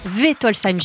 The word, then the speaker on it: Wittolsheim: L'idée est de Wittolsheim, j'ai peut-être mal prononcé, c'est bien Wittolsheim.